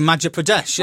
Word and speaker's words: Madhya Pradesh: It is the capital city in the Indian state of Madhya Pradesh. Don't know if I've said that right. I'll try it again. Madhya Pradesh. – I reckon Madhya Pradesh, yeah.